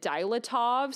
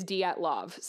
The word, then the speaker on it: Dyatlov: I think back then I called it Dilatov, so apologies. I have been practicing the pronunciation, and it seems like it is Dyatlov, so I'm sorry. If I'm not saying that right, I know it's not Dilatov, so sorry for saying that wrong like a year ago.